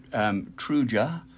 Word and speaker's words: Tuireadh: So it's actually Trúja. – Okay, well, if you could see it, that's not what it looks like. – Nope. T-U-I-R-E-A-D-H. But apparently, Trúja, if I'm even reading the pronunciation correctly.